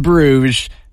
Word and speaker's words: Bruges: It looks like Bruges is pronounced Bruges.